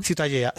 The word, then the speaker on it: Ciutadella: Ciutadella.